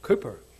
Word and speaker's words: Cowper: I call him Cowper. People tell me he's called Cooper, but that's how you pronounce it.